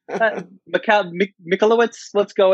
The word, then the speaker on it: Michalowicz: Michael Michalowicz. – That sounds right to me. I've been butchering his name since forever, and I only read it on the cover of books. So Michalowicz, let's go with that.